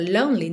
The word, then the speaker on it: lonely: Esse E é no meio da palavra lonely não é pronunciado, então fica lonely, tá? Do N você já vai direto pro L.